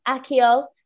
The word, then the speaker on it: Accio: It's Accio. three syllables.